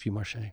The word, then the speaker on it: Vieux Marché: Is that how you say it, Vieux Marché? – Yes.